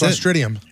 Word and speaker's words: Clostridium: Stool samples from people who got sick tested positive for, I'm gonna butcher this, Clostridium. – That's it, man. That's Clostridium.